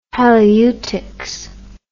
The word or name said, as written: halieutics